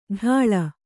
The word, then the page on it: ಢಾಳ: Kannada